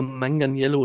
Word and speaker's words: Manganiello: Ähm, und dazu würde ich gerne tatsächlich dieses Mal auch sehen, wo ich fest in der stehe, ähm, Joe Magnelio oder Manganiello. – Äh, ich glaube, Manganiello spricht man es, glaube ich, aus.